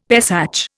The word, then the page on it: Pesach: Indian